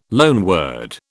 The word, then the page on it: loanword: British English